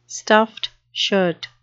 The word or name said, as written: stuffed shirt